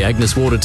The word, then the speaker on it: Agnes Water: Heading down to Agnes Waters. – No Mate, it's pronounced Agnes Water.